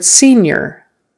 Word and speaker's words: senior: The word senior, like S-E-N-I-O-R, senior, you're older, you're a senior, or maybe you are in your last year of high school, you're a senior. It's spelled with an I-O-R. We pronounce it like an I-E-R, senior.